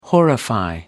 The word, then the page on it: horrify: American English